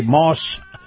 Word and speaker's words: Maus: And this portion of the program brought to you by Moss Family Automotive and Moss Nissan. Moss is spelled M-A-U-S.